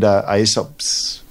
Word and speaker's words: Aesop: You mentioned Aesop's Fable. Is that how you pronounce it? – Aesop. Yep.